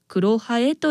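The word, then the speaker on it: 黒南風: 梅雨の時期の空が暗いことから、黒に南の風と書いてクロハエと言うんですね。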